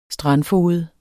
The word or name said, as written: strandfoged